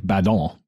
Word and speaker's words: Badin: Yes, Stephen Theodore Badin, and since he was French, it was probably like Badon, but we're Americans, we pronounce it Badin.